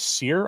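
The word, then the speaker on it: Sire: He actually pronounced his name as Sear.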